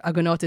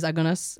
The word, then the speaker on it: Agunot: Agunot, or Agunas, as probably you're going to pronounce it. We're just, for the listeners out there, Agunot is Agunas and Toenet is Toenes.